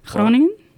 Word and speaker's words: Groningen: Did I pronounce it correctly? – Groningen? – Groningen? – Yes, very well. – Getting pretty decent at that. – But your G is a little bit like you are from the south of the Netherlands.